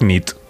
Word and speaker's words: nit: Y noche es nit. – Muy bien. Buena nit. Buena nit, es verdad. – Buena nit.